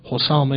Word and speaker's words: حسام: شاید تلفظ صحیحش فعال باشه، حسام.